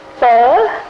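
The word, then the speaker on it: phở: Now, pho is spelled P-H-U with a little sort of a question mark over it, and it's pronounced pho.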